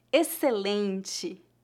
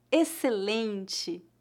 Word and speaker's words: excelente: Observe que as letras XC são pronunciadas como um S não pronunciamos excelente, faça de conta que as letras X C são a letra S.